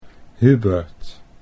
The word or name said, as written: Hubert